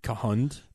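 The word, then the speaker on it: K'hund: So they have to go after Kund, right? – Yeah, which I always pronounce it like Kund. Or Kund. – Yeah. – But they said like Kahund. – There's an apostrophe in the name.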